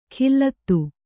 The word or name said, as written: ಕಿಲ್ಲತ್ತು